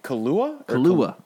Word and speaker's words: Kaluuya: Well, one person you won't have to wait and see because they're not going to be in the movie is Daniel Kaluuya, or what? How you say his name? I don't know. – Kaluuya.